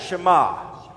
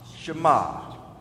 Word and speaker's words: Shema: The word hear is Shema. Go ahead and say that, Shema, Shema. O Israel, Yisrael, it's three syllables to it, and it's spelled Y-I-S is the first syllable, then R-A for the second syllable, and then E-L.